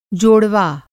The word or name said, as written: जोड़वा